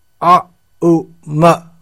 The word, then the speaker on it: Om: In other words, om is pronounced a, u, ma, when these three syllables are joined together, a, u, ma, then we get aum. Oh, it's written in English, A-U-M.